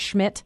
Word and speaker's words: Schmidt: Does she pronounce it Schmidt? – She does.